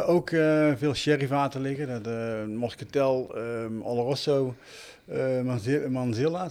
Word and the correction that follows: Manzanilla: zeg ik dat goed? – Manzanilla. – Manzanilla, ja, ik, heb, alleen verstand van whisky en niet van sherry.